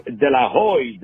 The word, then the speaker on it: De La Hoyde: His name is Nick De La Hoyde. Let me spell it for you. D-E-La-L-A. Hoyde, H-O-Y-D-E.